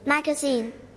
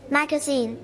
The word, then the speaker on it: magazine: Magazine. – One more time. – Magazine. – Okay, เราจะ stretch ที่พยางค์สุดท้ายนะคะ.